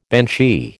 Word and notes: banshee: American English

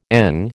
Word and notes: ENG: American English

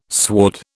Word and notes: sward: British English

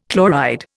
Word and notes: chloride: Indian

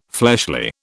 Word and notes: fleshly: British English

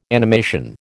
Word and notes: animation: American English

